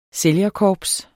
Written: sælgerkorps

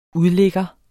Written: udligger

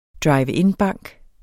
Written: drive-in-bank

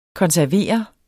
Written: konservere

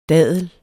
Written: dadel

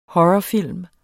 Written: horrorfilm

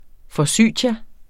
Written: Forsythia

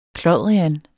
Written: klodrian

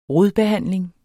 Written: RODBEHANDLING

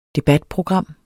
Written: DEBATPROGRAMMET

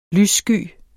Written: lyssky

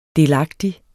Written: delagtig

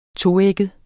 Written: toægget

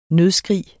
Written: nødskrig

